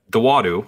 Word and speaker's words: Dawodu: Dawodu or No. Dawodu. I had that right.